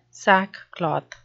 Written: sackcloth

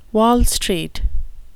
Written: wall street

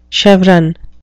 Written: chevron

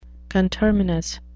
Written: conterminous

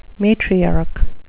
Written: matriarch

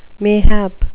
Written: mayhap

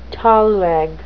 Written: thalweg

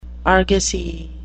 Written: argosy

